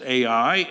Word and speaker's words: Ai: And it is pronounced Ai, and it is spelled A-I, as far as we know, if we transliterate it into English.